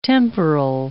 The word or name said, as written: temporal